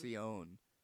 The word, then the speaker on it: Tentacion: He spells it T-E-N. – But T-A-C-I-O-N. It's pronounced Tentacion.